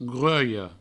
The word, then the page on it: gruelha: grueio; Provençal